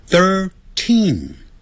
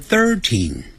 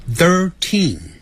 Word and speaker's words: thirteen: 十三，thirteen， t h i r t e e n，所以 t h i r 有一个 t h，舌头就要上齿跟下齿轻轻咬住舌头吐出来，third，然后 third，赶紧往里头说啊，thirteen，这个有轻重音之别，不要念成 thirteen。那个 teen 要重读，也就是读出来的时候声音比较重一点 thirteen。